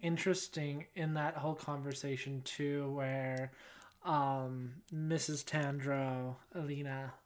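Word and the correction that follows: Enina: is that how you say it? Alina? Alina? – Anina.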